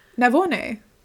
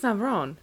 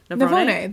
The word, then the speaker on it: Navone: Navone. – I think it's Navarone. – Navone. There's no R in that name. He lost... – Navone?